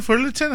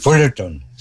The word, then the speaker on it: Fullerton: Fullerton, 90, sí lo digo bien, ¿verdad? Fullerton, así es. – Fullerton, sí, sí.